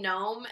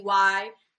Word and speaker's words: Gnome: Much you can find Noey Gnome, and people struggle with the word gnome. It's a silent G. So Noey Gnome.